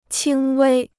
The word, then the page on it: 轻微: qīng wēi